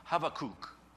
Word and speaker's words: Habakkuk: Because in Hebrew, it's this CH sound that's kind of a guttural sound that we don't really make in English, so we English speakers would say it wrong anyway. It'd be something like Habakkuk.